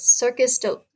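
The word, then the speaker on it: Cirque du Soleil: And I'm currently in the process of Circus Olé. I can't pronounce it. – Cirque de Soleil.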